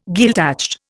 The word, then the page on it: Gaeltacht: Indian